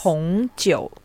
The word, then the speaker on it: 红酒: So it's 红酒. – 我要红酒. – 对. Yes. Oh, exactly a nice pronunciation.